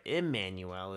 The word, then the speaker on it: Immanuel: It's Immanuel, or Immanuel is how you pronounce that.